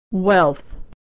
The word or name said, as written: wealth